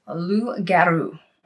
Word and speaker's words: loup-garou: In French, it is pronounced loup-garou, okay?